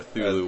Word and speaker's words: Cthulhu: Cthulhu or Thulu, whatever. – Yeah, how do you pronounce it? – I don't know how to pronounce it. – Spell it. – I think it's Cthulhu. – It's like C-T-H-U-L-H-U. Cthulhu. That's it sounds great to me.